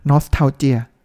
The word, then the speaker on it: nostalgia: nostalgia